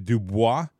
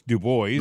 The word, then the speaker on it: DuBois: I pronounced it Dubois. No, it's Dubois.